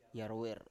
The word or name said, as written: yarwer